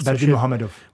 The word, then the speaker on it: Berdimuhamedov: A takhle, kdybych jsme zkoumali otce Turkmenů, Turkmen Bašiho, Gurbanguly Berdikmuhamedova, teď jsem to řekl správně skoro, ještě jednou, Gurbanguly Berdikmuhamedov, Berdimuhamedov. – Muhamedov,